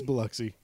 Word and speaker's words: Biloxi: Is it Biloxi? – It's Biloxi, yes.